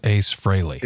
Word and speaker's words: Frehley: Well, that was the whole takeoff on his band's name was Frehley's Comet to kind of rhyme with Haley, I always thought.